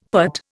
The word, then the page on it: putt: Indian